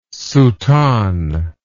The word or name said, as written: soutane